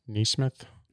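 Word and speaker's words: NeSmith: Matthew Neesmith.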